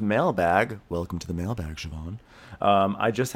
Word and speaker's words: Siobhan: Siobhan is, you know, it's pronounced Siobhan. I mean, not pronounced, spelled Siobhan, but, you know, Siobhan.